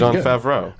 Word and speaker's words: Jon Favreau: All right, Jon Favreau.